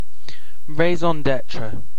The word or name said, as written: varoluş